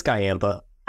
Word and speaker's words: Giampa: It's Giampa.